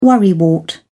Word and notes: worrywart: British English

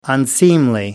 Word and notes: unseemly: American English

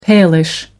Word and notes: palish: American English

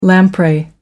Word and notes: lamprey: American English

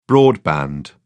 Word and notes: broadband: British English

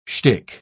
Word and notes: schtick: British English

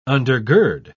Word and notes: undergird: British English

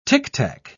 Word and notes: tictac: British English

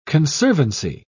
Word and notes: conservancy: British English

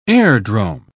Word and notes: airdrome: British English

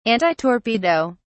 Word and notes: antitorpedo: British English

